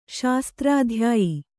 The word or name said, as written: ಶಾಸ್ತ್ರಾಧ್ಯಾಯಿ